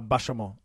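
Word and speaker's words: Bachaumont: Bachamont. – Bachamont. – So it's like hotel Bachamont. – Bachamont. – Oh, okay. Bachamont. Yeah. But they say it like, it looks like Bachamont, but it's Bachamont.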